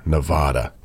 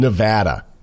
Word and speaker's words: Nevada: Also, we got several messages saying, stop pronouncing Nevada, Nevada. It's Nevada.